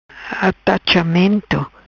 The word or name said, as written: Atachamento